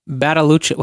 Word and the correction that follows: Badalucco: How do you say his name? – Badalucco. No.